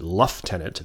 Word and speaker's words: lieutenant: He joined the Royal Navy in 1777 as a lieutenant, sorry, they pronounce it luftenant at that time, and he worked his way up through the ranks in your classic Horatio Hornblower sense.